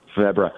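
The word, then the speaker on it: Febvre: Febre.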